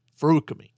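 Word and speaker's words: Feruchemy: It's Ferukemi. That's how it's pronounced, right? – Don't separate the sounds. Ferukemi.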